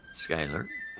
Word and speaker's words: Schuyler: S-C-H-U-Y-L-E-R. Oh, I guess it's Schuyler. I'm sorry. – Schuyler. – It's got a C-H in there is why. But I guess it's pronounced Schuyler.